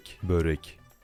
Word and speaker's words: Börek: Warte mal Aussprache. Moment. Pronunciation. Nicht Börek. Spricht man das aus? In Türkisch, genau. In Dänisch? Okay. – Börek.